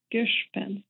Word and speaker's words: Gishpen: It rhymes with fish and with fence. Gishpen.